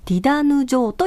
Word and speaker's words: ティダヌ城: これ、太陽の門と書いて、ディダヌ城と読みます、えー、この市民ミュージカルディダヌ城の出演者を大募集しています。